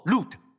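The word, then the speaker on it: Lut (לוט): 亲密，阿妹，我喜欢曼子的概念，因为就是罗德，他的名字是读作 Lut，好吧，Lut 在希伯来文就是曼子。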